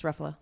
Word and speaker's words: Ruffalo: It's Ruffalo, like Pretty Buffalo.